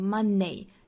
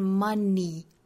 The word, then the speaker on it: money: 最后还要补充一个呢，就是 money 这个词，大家不要把它读成 money, 应该是 money. 尾部呢是一个紧音，是长元音 e, seat 里面这个长音 e 的短板，也就是我们去掉长音符号，但是口型的紧张度是完全一样的，我们发 money, seat, e.